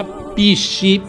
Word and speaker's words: PSSI: Pissi, partito super sovranista italiano, si pronuncia Pissi, P-S-S-I, Pissi.